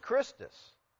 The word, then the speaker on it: Christus: Who's Christus? Well, that's how the Greeks pronounced the name of Christ.